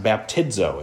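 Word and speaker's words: baptizo (βαπτίζω): But the Greek word for baptize is actually pronounced baptizo. It's pretty close to the English word.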